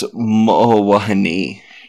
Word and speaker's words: Mo'owahine: There's also the legend of the, how do you say this one? Mo'owahine? – That's Mo'owahine.